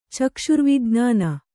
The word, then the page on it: ಚಕ್ಷುರ್ವಿಜ್ಞಾನ: kannada